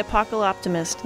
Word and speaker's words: Apocaloptimist: Apocaloptimist, am I pronouncing that correctly? – Yeah, it's the Apocaloptimist, that's right.